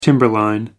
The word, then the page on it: timberline: American English